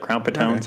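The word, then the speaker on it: Crown Petone: It takes place at the Crown Patone. Am I saying that right? I forgot. – Yeah, I think you're right. Crown Patone sounds right.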